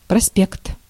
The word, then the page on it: проспект: /prɐˈspʲekt/